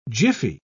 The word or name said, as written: jiffy